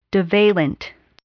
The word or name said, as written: divalent